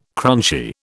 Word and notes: crunchy: British English

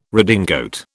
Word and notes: redingote: British English

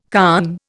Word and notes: conn: Indian

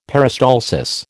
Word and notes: peristalsis: American English

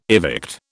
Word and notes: evict: British English

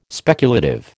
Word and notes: speculative: American English

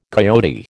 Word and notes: coyote: American English

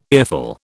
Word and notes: earful: British English

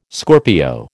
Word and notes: Scorpio: American English

